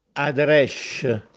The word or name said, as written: Aderèsce